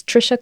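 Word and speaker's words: Tricia: Check in the show description so you can join it, or you can just go to my website, which is Trisha Carr Charm, and it's easy to find. My name is spelled T-R-I-C-I-A-C-A-R-R, and there's like a pop-up that'll help you to sign up for my mailing list.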